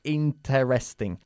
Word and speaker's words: interesting: No decimos interesting. La primera E se pierde. Decimos interesting. – Interesting. – ¿Qué tal la clase? It was... – It was very interesting. – Very interesting. Pero enfatizamos la primera sílaba.